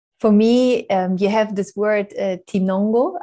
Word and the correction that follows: tinogona: I don't know if I pronounce it correctly. – No, tinogona, tinogona, which tinogona.